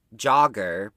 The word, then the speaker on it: Jogr: So, yeah, I'm drinking Jogger, but they spell it J-O-G-R.